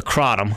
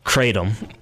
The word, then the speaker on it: kratom: The Crotum, mm-hmm. or as my friend in Fredericksburg corrected us. Yes, we have Fredericksburg. Listen, Kratom, mm-hmm. – whichever. – It can be pronounced either.